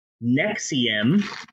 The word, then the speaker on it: NXIVM: So, it's time to do a sort of mini shap about an organization known as NXIVM, which the members of the cult pronounced Nexium.